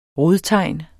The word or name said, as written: rodtegn